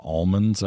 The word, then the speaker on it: almond: You all know it as almonds out there, but we don't say we don't say the L. It's a silent L.